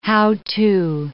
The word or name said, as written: how-to